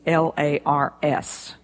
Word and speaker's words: Lars: my husband, Lars, and I better tell you that his name is L A R S. That's a hopelessly difficult name for most people, unless they're Scandinavian in background, but his first name is Lars, rhymes with stars.